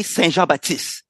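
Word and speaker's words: Saint-Jean-Baptiste: Well, actually, you also did pronounce it pretty well, Carl. We say Saint Jean Baptiste.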